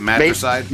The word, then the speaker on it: Matricide: Um, your latest album was Mattress. Am I pronouncing that right? – Matricide. Ma- matricide. Matrix. It's the Long Island tongue, man.